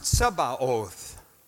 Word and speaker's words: Sabaoth: The word should be pronounced Tzebaoth. The German has the correct pronunciation.